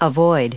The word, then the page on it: avoid: American English